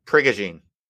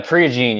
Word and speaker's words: Prigogine: It's Ilya Prigione. Just type in Ilya, Prigione. – Oh, I think it's pronounced Prigogine. – Yeah, Prigogine. Yeah.